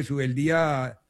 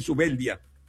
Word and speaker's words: Zubeldía: Pero yo sé que hablamos de Zubeldía con tilde en la I, como se pronunciaba antiguamente, porque ahora los relatores le dicen Zubeldía.